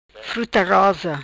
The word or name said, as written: Fruta Rosa